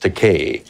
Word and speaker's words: Takei: I said, my name is pronounced Takei.